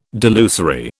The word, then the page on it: delusory: British English